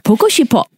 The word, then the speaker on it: 보고 싶어: Ship as in S-H-I-P? – Yeah, ship. – Okay. – Yeah. – 보고 싶어. – 보고 싶어. But when you say it as a word, it doesn't really matter whether you say she or ship because the next syllable is already the P.